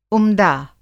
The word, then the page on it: उमदा: Hindi